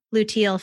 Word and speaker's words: luteal: luteal phase, am I pronouncing that correctly? – Yeah.